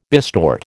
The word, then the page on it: bistort: American English